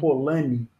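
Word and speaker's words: Polanyi: Polani. Polani se escreve é P-O-L-A-N-Y-I. É Polani, N-Y-I.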